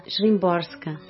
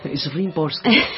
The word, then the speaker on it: Szymborska: O Torrone fez uma base para uma música e a gente encaixou um poema da Svetlana Szymborska. Todo mundo me perdoe se eu pronunciei errado. – Szymborska.